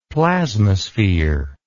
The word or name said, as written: plasmasphere